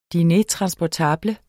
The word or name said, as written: DINEREN